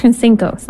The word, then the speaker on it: Sinkos: There's an episode, I think more than one episode I did with Dr. Tracy Sinkos, I think I'm saying the last name wrong. It's been a long time. Sinkos, that's it, who is here.